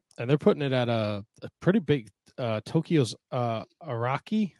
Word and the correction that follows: Ariake: Am I saying that right? – I think it's Ariake.